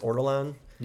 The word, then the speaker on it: Ortolan: Are you ever curious about eating, is it pronounced Ortolan? – Yeah, I think it is.